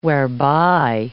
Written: whereby

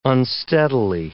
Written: unsteadily

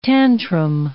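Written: tanuki

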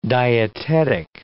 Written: dietetic

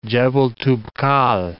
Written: Toubkal, Jebel

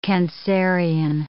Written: Cancerian

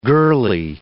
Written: girly

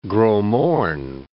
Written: Gros Morne National Park

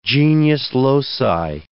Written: genius loci

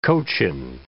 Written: Cochin

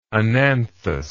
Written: ananthous

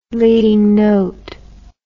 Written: leading note

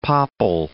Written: popple

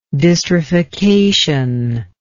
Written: dystrophication

